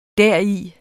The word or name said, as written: deri